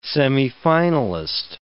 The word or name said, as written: semifinalist